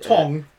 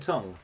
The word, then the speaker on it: Tunng: Tong. – T-U-N-N-G. Who I've always thought is pronounced Tong.